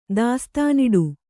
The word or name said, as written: ದಾಸ್ತಾನಿಡು